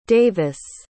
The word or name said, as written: davis